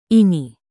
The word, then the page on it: ini: indonesian